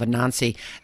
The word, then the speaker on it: Anansi: Anansi, I believe I'm pronouncing Anansi it.